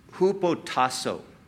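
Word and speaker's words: hupotasso (ὑποτάσσω): Now, the word submissive, the word that is translated submissive or submit, is a Greek word that is pronounced hupotasso.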